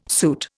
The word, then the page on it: suit: Indian